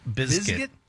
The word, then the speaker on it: Bizkit: I've never heard someone pronounce the Z so hard. – Yeah, I was like visualizing it in my head because we just say biscuit, but I mean it really is Bizkit.